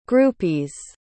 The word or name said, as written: groupies